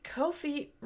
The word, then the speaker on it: Ko-fi: Ko-fi rhymes with no fee.